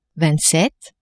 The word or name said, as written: vingtsept